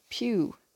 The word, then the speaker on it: piw: The word for who in Cornish is pew. This is spelt P-I-W.